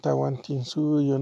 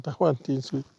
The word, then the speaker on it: Tahuantinsuyo: Del Tawa Intisuyo. Del Tahuantinsuyo, ¿no? Del Tahuantinsuyo.